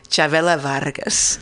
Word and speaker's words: Chavela Vargas: Chavela Vargas. – Oh, yes, yes. – I'm sorry, let me say that correctly. Chavela Vargas. – Mm-hmm. – I don't really roll my R's, but I know what people expect from me.